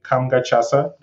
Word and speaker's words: Kamga Tchassa: All of them, you can find them with my name, Kamga Tchassa, the way Jay pronounced it so beautifully.